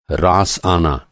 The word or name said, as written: रास